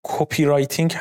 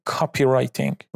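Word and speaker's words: copywriting: که توی فارسی بعضی وقتا اونو کپی رایتینگ هم میگن، اما تلفظ درست کلمش میشه کاپی رایتینگ.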